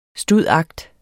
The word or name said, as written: stud.act.